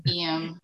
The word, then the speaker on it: Ian: Ian. – And you must get it mispronounced all the fucking time. – Yes. – So like you told me how to say it, it's like Liam without the L.